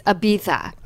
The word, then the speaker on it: Ibiza: The other day, someone told me, if you're going to name a bronzer Ibiza, you need to say it correctly. It's Ibiza.